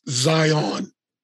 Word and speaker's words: Zion: And it's Zion, not whatever you said.